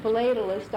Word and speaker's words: philatelist: My daughter tells me it's pronounced philatelist. I don't know the proper pronunciation, but it's a stamp collector.